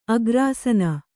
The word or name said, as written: ಅಗ್ರಾಸೀನೆ